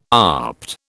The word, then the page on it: opt: British English